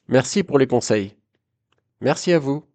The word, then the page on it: merci a vous: french